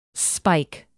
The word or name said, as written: spike